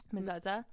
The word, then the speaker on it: mezuzah: Steve handed me my mezuzah. I could be saying that wrong. – Sorry. I think it's fam. – mezuzah. Okay. I think.